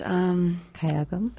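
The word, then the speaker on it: gayageum: Kyagam.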